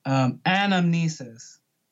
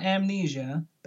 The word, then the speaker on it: anamnesis: And he invokes the idea of anamnesis, not amnesia, but anamnesis, or I believe I'm saying that right.